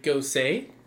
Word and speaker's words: Gose: It's a raspberry ghost. Raspberry Gose? – Sure. – Raspberry Goose? – G-O-S-E.